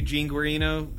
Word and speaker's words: Guarino: Today on the show, we've got somebody I look up to, Gene Guarino, founder of the Residential Assisted Living Academy.